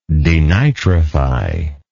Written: denitrificator

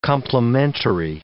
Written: complementary

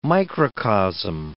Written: microcosm

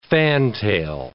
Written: fantail

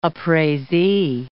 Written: appraisee

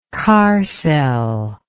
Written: kerr cell

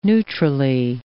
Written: neutrally

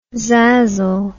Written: zazzle

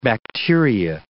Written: bacteria

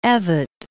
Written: Evatt

